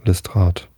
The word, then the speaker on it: Lestrade: Lestrade oder, na, was sagt der Chat? Habe ich das jetzt richtig ausgesprochen? Ja, Lestrade.